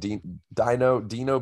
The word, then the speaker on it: Dino: And Dino Bay.